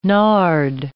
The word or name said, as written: nard